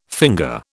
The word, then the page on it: finger: British English